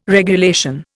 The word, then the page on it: regulation: Indian